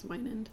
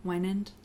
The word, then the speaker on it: Wynand: And Samuel's alias is another character in the book, Gail Winand. I think that's how they say it. – W-Y-N-A-N-D? – Mm-hmm. – I think it's Winand. – Winand? – Yeah. – I think I've never heard anybody say it, so... – you're better at pronouncing things than me, so I'm going to go with what you said.